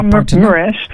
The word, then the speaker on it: Mraz: Spreek je dat zo uit? – Want ja, Mraz. – Mraz. Oké, één van die twee liedjes die konden we niet vinden.